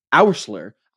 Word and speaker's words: Oursler: Dr. Gene Oursler.